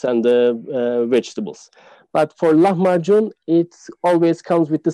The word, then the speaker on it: Lahmacun: Is perfect. That? You're a natural in Turkish. That's how you say it. Lahmacun, exactly. The origin of the word actually comes from Arabic, lam abecin, meaning ground meat. In Turkish, we pronounce it as lahmacun, like you said.